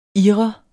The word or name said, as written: ir